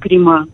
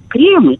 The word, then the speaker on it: кремы: крема. Кремы, кремы, увы. Крема, это, ну, нет такого и произношения этого слова в русском.